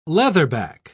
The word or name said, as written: leatherback